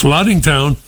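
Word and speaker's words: Lattingtown: Cousin Brucey, I live in a place called Laddingtown, which is in the north shore of Nassau County. – Oh, and spell that. Laddingtown. I don't know this one. Spell it. – L-A-T-T-I-N-G town. – That's Laddingtown. All right.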